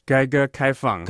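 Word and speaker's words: Geiger Kaifeng (改革开放): Can you help me with pronunciation? Is it Gage Kaifeng? – Geiger Kaifeng. – Geiger Kaifeng. – Geiger Kaifeng, yeah.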